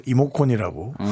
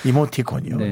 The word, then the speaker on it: 이모티콘: 그리고 또전 이건 발음을 잘못해서 이모콘이라고. 아, 네. 주목콘도 아니고 네. – 이모티콘이요 네.